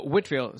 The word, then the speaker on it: Whitefield: Whitfield, se llama George Whitfield. Es pronunciado como Whitefield, como campo blanco, pero los británicos no hablan inglés, ¿verdad? No pronuncian bien. Es escrito Whitefield, pero se dice Whitfield, así como la salsa es Worcestershire.